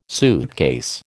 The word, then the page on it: suitcase: American English